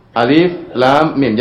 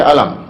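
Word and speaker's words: Alif, lam, mim (الم): Oleh karenanya to, sin, mim tidak boleh dibaca, tapi dibacanya per huruf. To, sin, mim. Alif, lam, mim. Jangan dibaca alam, keliru.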